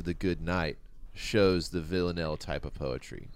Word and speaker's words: Villanelle: All right, V I L L A N E L L E. What do you think, Villanelle? – I mean, it sounds like you pronounced it wonderfully.